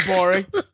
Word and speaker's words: Gborie: Love Blackjack starring David Gborie, the G is silent on Twitter, Cool Guy Jokes 87 on Instagram.